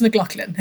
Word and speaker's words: McLaughlin: I said, I'll change my name to yours so long as you start pronouncing it the correct way, which is McLaughlin.